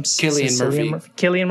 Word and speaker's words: Cillian: Cillian Murphy.